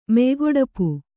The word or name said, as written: ಮೆಯ್ಗೊಡಪು